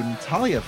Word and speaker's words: Natalya: So I think we could give kudos to Natalya for creating that Definitely.